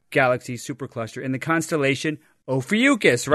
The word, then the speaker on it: Ophiuchus: I'd like to pronounce this... – Ophiuchus, but some people were pronouncing it Ophiuchus, so whatever.